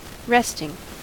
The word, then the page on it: resting: American English